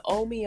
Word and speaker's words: Omi: But the Omi Omai, so it's spelled O-M-I, which is my nickname. O-M-I-O-M-Y.